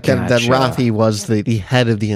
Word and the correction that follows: Rafe: I'm not sure why you guys keep saying Rafi. – Because is that how it's pronounced? – It's Rafe. – Rafe? Okay. – Rafe. – Rafael, but he calls him Rafe.